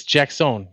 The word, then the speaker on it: Jackson: It's Jackson.